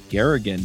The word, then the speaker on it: Garrigan: This week, we're talking to Michael Garrigan.